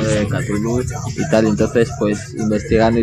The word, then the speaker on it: Cthulhu: Yo no sé si será en todo el territorio nacional o será solo en Cantabria, pero tenemos una movida que nadie sabe si se dice Cthulhu o se dice Tulu y anda todo el mundo peleándose. – No, bueno, se pronuncia Cthulhu.